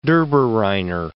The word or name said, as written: Dobereiner